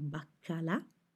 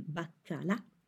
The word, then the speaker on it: baccalà: Per secondo, as a second course, per secondo mangiamo il baccalà, codfish, il baccalà, with a stress on the last A, il baccalà, il pesce fritto, fried fish, fritto, fried, l'arrosto, roast beef, o le salsicce, sausages.